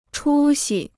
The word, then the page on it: 出息: chū xi